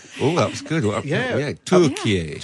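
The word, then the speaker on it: Türkiye: So, is Turkey spelt the way that Rishi Sunak spelt it? Is that how, is it pronounced the same, or is it spelt, do we pronounce that differently? – Turkey.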